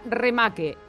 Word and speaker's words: remake: La paraula remake, pronunciat en castellà, remake. R-E-M-A-K-E.